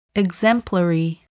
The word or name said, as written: exemplary